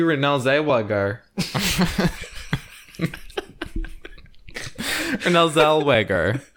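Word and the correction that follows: Renée Zellweger: How do you pronounce her name? – Renee Zellweger.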